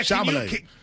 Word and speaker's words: Chalamet: Yeah, Chalamet.